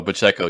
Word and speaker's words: Pacheco: So, Pacheco.